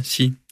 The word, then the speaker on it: Xi: Xi doesn't look... Er det rigtigt udtalt? – Ja, Xi.